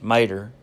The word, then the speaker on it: tomato: Other examples are potato, pronounced tater, tomato, pronounced mater, and tobacco, pronounced backer.